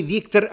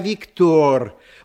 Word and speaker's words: Виктор: Во-первых, не Виктор, а Виктор. Во французском языке ударение на последнем слоге.